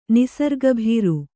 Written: ನಿಸರ್ಗಭೀರು

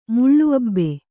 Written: ಮುಳ್ಳುಅಬ್ಬೆ

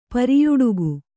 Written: ಪರ್ಯಟಕ

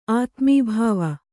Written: ಆತ್ಮಭವ

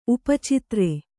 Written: ಉಪಚಿತ್ರೆ

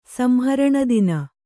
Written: ಸಂಹರಣದಿನ

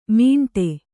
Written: ಮಿಂಟೆ

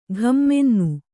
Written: ಘಮ್ಮೆನ್ನು

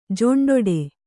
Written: ಜೊಂಡೊಡೆ

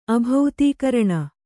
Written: ಅಭೌತೀಕರಣ

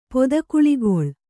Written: ಪೊದಕುಳಿಗೊಳ್

